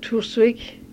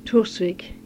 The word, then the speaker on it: Torsvik: Tusvik. Tusvik. – Tusvik. – Yeah. – How do you spell that? – T-O-R-S-V-I-K.